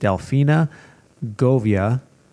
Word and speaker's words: Delfina Govia: I am here today with Delfina Govia.